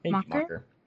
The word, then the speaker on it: Macher: I just can't figure out how to pronounce his name because it's M-A-C-H-E-R. – Macher? – Macher? Macher? Macher. – So I feel like it's that hard C-H sound, Macher. – I can understand that. Yeah. – Like that slightly Germanic sound.